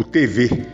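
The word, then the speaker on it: Thevet: O principal relato para você entender no componente histórico dos tupinambás, você vai ver pelo franciscano francês, o TV, o TV é, se escreve T-H-E-V-E-T, o T mudo, uh, André TV.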